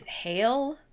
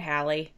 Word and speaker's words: Halle: I don't know if you pronounce her name Halle or if it's Hale. It's Halle. It's not Halle Berry. It's Halle Bailey.